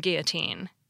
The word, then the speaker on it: guillotine: Yet in armadillo, we pronounce them like an L, now let's consider guillotine from French. Most English speakers pronounce the two L's like a Y, as it is in French, too.